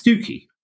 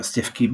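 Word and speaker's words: Stiffkey: And we've got a blue that is, because we have lots of strange colloquialisms in the English language, there's a place in Norfolk that's spelt Stifkey, but it's locally pronounced as Stookey.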